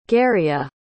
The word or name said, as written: garrya